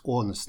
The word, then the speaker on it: honest: то есть скажем she is honest, например. Обратите внимание, что буква H в этом слове немая, она не читается совсем. Honest, не honest.